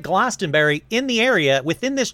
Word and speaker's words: Glastonbury: In fact, the town of Glastonbury, let's see how to pronounce that. I don't want to say everything wrong today. – Glastonbury. – Okay, fine.